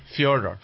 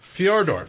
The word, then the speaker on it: Fyodor: You know how to say the first name? It's Fyodor. Fyodor.